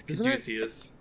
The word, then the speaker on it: Caduceus: It's Caduceus.